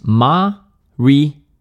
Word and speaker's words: Mariota: Så det siges Mariota. Mariota.